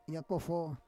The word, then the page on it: yakofo: Roon